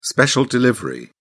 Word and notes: special delivery: British English

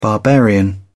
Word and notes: barbarian: British English

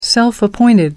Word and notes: self-appointed: American English

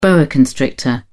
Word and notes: boa: British English

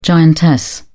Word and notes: giantess: British English

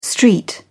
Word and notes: st. joseph: British English

